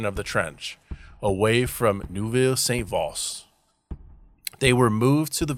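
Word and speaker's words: Neuville-Saint-Vaast: They were in rotations between the trench and the ruins of Neuve Saint-Vaast. Sorry, I said that wrong again. Neuville Saint-Vaast.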